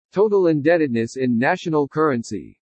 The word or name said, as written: TOTAL INDEBTEDNESS IN NATIONAL CURRENCY